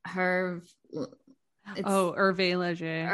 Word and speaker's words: Hervé Léger: Hervé Léger.